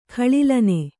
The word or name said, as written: ಗಳಿಲನೆ